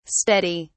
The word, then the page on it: steady!: British English